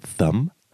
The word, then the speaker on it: thumb: Thumb. – Thumb. – Du musst deine Zunge oben an deine Vorderzähne drücken.